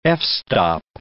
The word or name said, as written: f-stop